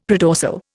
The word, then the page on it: predorsal: Indian